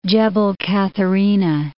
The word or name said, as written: Katherina, Gebel